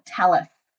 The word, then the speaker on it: Tallies: Well, first of all, tallies rhymes with Alice.